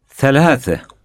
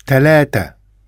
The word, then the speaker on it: ثلاثة: Another common difference in pronunciation is that the Arabic letter th is often pronounced t. Listen to the Arabic word for three pronounced in the two different ways. – ثلاثة. – ثلاثة.